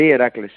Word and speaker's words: Heracles: Yo digo Heracles, ¿se dice así en holandés o cómo se dice? – Sí, Heracles, sí. – Heracles, sí. – Qué fácil, se lee cómo se escribe.